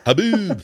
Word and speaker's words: haboob: So you can have it in the dust storm that you guys Haboob. Get there in Arizona? Haboob. There you go.